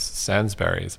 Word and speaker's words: Sainsbury's: So you mentioned your parents, and for people who don't know, they were behind this Sainsbury's.